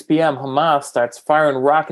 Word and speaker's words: Hamas: Yeah, so Hamas, am I saying that right now? Hamas? – Yes, that's correct.